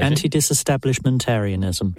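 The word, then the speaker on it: antidisestablishmentarianism: Anti-establishmentarianism. – Don't know. – I don't know. I can't even. I'm cooking under pressure. – Let's go with the official pronunciation. – Anti-disestablishmentarianism.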